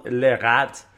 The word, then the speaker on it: Lerat: And of course, your Paul Lerat basses. – You said it right. – There we go. – Lerat, yep.